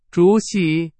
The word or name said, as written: 主席